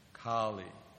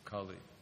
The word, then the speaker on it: Kali: But more or less, Kali has been defined as lack of religious principles. That's different. That's Kali. This is Kali. Kali. It's a different word. There's a long A instead of, this is a too short.